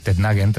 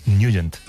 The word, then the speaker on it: Nugent: Hombre, siempre lo hemos llamado en el barrio Tednagen o Tednagen. – Claro, yo era de Tednagen. – Pero la pronunciación correcta es Nugent.